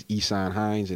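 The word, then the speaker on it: Ihsan Hines: And my personal Facebook page is Esan Hines, and that's spelled Ihsanhines.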